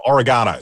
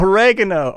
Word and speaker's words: oregano: No, oregano. Sorry. – Oh, oregano. Oregano. You say it. Okay, oregano. – Put emphasis on the different syllables.